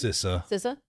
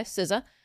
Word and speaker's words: CISA: CISA, however you choose to pronounce it. CISA. Yeah. – Okay, CISA.